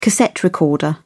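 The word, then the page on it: cassiterite: British English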